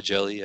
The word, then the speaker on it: Hjelle: He's got a weird spelling on his name. It's spelled H-J-E-L-L-E, but he goes by Jelly. Everyone calls him Jelly.